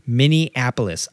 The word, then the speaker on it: Minneapolis: This weekend is going to be IMS in Minneapolis. I pronounced it right for the first time in my life. Oh, God, that felt good. Normally I say Minneapolis for whatever reason.